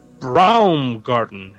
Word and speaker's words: Baumgarten: Following letter a couple of weeks ago from Eric Baumgarten. Am I pronouncing that right, Dave? – Yes.